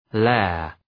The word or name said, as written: lair